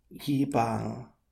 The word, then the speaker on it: Yibang: Yibang, yibang. So that's got a long A in the second part. Bang.